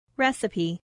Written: recipe

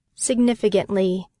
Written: significantly

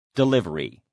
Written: delivery

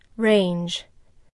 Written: range

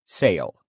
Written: sail

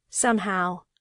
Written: somehow

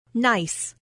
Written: nice

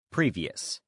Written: previous

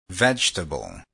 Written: vegetable

